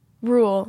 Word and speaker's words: Ruel: The artist is R-U-E-L. – Rule. – Rule? – Yeah.